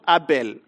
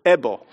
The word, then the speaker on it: Abel: Just to make, maybe just to highlight a few of them, I was pronouncing this word at home, Abel, and then my son was telling me, not Abel. Abel, that is Kenyan. You pronounce it Ebo.